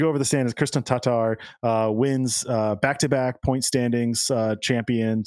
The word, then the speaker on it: Tatar: That two A's in Tatar are pronounced differently, apparently. – Oh, and so I'm trying it that. Tatar.